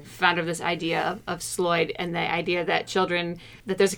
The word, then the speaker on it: Sloyd: but the concept is Sloyd. That's the American pronunciation. I've seen the American spelling as S-L-O-Y-D, which is handcrafts, if you will.